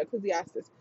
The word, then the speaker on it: Ecclesiastes: There you go, Ecclesiastes.